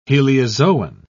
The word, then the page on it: heliozoan: British English